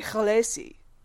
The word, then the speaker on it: Khaleesi: And I heard an interview with him on a podcast where he was like, yeah, Khaleesi is not pronounced like that. in the actual language that I made, E and I are not pronounced the same. You would say Khaleesi.